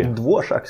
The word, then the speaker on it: Dvorak: Som, och det uttalas inte säkert inte ens så, men jag, det. – Dvorak skulle jag säga. – Ja, det är nog så det är.